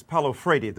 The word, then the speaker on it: Paulo Freire: Paulo Freire, the correct pronunciation of the Brazilian philosopher, talked about pedagogy of the oppressed, learning of people who have been oppressed.